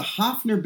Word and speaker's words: Hofner: And that electric piano is do you say Hofner or Hohner? Because I've always heard Hofner bass, but like a Hohner harmonica or something. – I don't know. I always pronounce it. It's a Hofner bass and a Hohner piano, I think.